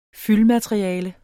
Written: fyldmateriale

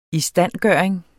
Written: standfugl

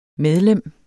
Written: medlem

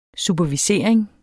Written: supervisering

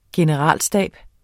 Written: generalstab

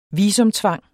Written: visumtvang

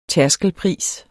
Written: tærskelpris

